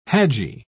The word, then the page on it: hadji: British English